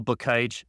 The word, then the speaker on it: Bakaich: Otherwise, find me on LinkedIn, Michael Bakaich, B-A-K-A-I-C, as you see in the name. That's how you pronounce it.